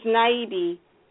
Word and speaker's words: Naibi: It's Naibi.